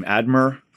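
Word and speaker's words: Eadmer: So this book begins, The Glories of Heaven, by, how do you say his name? Edmar. – Yeah.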